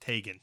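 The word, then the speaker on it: Teigen: the queen of Twitter, Chrissy, and her name is pronounced Tegan. – Tegan. – Not Tegan, it's Tegan.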